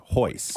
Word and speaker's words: Royce: His name is spelt R-O-Y-C-E, Royce, but it's pronounced Hoyce.